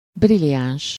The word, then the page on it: brilliant: American English